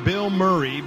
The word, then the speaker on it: Bilmuri: that band Bill Murray, but it's spelled B-I-L-M-U-R-I, not like the actor Bill Murray, on 92.7 WQLZ.